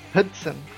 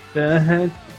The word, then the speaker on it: Hudson: Qual seria a pronúncia certa? Já corrigiu. – Hudson. – Hudson.